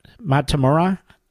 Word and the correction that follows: Metamora: Michigan? How do you pronounce that, Dave? – Metamora.